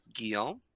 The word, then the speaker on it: Guillaume: This was Gilwam Leonard. No idea if I did that correctly, but I believe it's Guillaume.